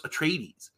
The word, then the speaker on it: Atreides: Although for the longest time, because I had only read it, I didn't know how to pronounce Atreides. Until I realized that in one of Gurney Halleck's songs, he actually rhymes it with the word ladies.